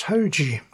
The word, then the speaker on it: Hoji: Oh, I just checked, and his name is pronounced Hoji. Hoji means today in Portuguese.